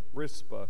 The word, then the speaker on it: Rizpah: Rizpah.